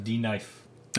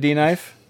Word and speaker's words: D-Knife: It's also going to be colored by D. Kniff and lettered by Simon Boland. – It's pronounced D-Knife. – Oh, is it D-Knife?